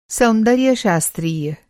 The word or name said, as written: सौंदर्यशास्त्रीय